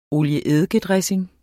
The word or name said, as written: olie-eddike-dressing